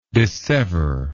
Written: disseveration

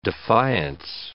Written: defiance

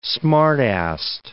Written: smart-assed

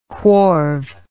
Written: wharve

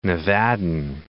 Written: Nevadan

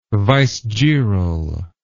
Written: vicegeral

